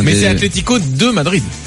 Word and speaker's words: Atlético: L'accent sur le... C'est Atlético. – Ah oui, non, les accents, j'ai vite abandonné, moi. – Ouais, c'est compliqué, hein, mais voilà. C'est ce qu'on appelle l'accent tonique et c'est sur le... Là, c'est accentué sur la deuxième saison.